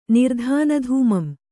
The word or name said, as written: ನಿರ್ಧಾನಧೂಮಂ